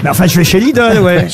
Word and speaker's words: Lidl: Il n'y a pas de O, c'est Lidl. – Oui, Lidl.